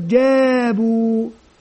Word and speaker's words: جابوا: إذن عندما أتهجى مثل هذه الكلمات، مثلا كلمة مثل كلمة جابوا، جاء ألف مد طبيعي حركتان، جابوا جابوا، واو مد طبيعي حركتان، جابوا، ألف صفر مستدير لا تنطق، جابوا، وهكذا في كل الكلمات.